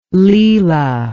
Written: Lela

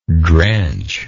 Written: drenchingly

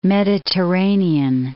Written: Mediterranean Sea

